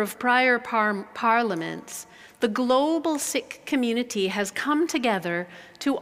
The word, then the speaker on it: Sikh: That's spelled S-I-K-H, and it's properly pronounced Sikh. I used to think it was pronounced Sikh, but the community is called the Sikh community, and the religion is called Sikhism.